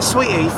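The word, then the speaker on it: Saweetie: Sweetie? – Sweetie.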